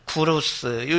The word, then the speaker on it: 고레스: 페르시아의 왕족에게만 붙어있던 이름이었는데 고레스는 태양이라는 페르시아어로 사이러스는 그리고 실제로 페르시아 발음을 누가 복원했는지 모르겠지만 학자들에 의하면 영어로는 사이러스 그 다음에 우리는 고레스 이렇게 발음하는데 실제 말은 구루스 요런 요게 좀 비슷하다고 합니다.